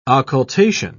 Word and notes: occultation: British English

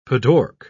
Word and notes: padauk: British English

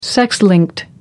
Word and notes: sex linked: American English

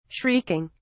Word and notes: shrieking: British English

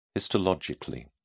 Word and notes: histologically: British English

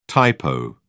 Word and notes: typo: British English

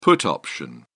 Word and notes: put option: British English

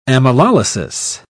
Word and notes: amylolysis: British English

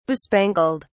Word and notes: bespangled: British English

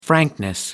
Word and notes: frankness: American English

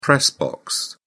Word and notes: press box: British English

